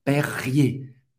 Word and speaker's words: Perrier: Perrier, perrier, perrier, ok? Et R, er, généralement, no final d'une uma se pronuncia E.